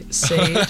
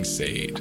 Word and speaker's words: Sade: When a particular friend of ours pronounced Sade Sade. – Oh my god, I yelled it. I heard it from like genuinely 10 feet away. My ear perked up. I was like, it's Sade. It's Sade.